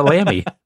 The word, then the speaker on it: Lamy: I mean, I can't afford an Audi, so I have a Lamy. yeah, four letters, two syllables.